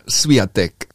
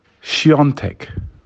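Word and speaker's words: Świątek: Hubi und Iga Swiatek. Äh, da weißt du selber, dass da. Nee. Da stimmt gar nichts. Die korrekte Aussprache lautet Iga Siontek. Siontek.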